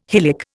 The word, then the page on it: hylic: Indian